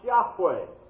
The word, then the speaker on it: Yahweh: We believe, we don't know for sure, we believe that this name of God, the very name that God gave through Moses at the burning bush, is pronounced Yahweh, Y-A-H-W-E-H.